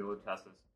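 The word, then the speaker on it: Luteces: How do you say their name? Lutesses. – Yeah, the Lutesses.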